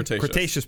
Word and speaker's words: Cretaceous: And, you know, you're talking about the end of the Cretaceous period. Am I saying that correctly? – Cretaceous period.